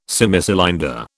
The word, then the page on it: Semicylinder: British English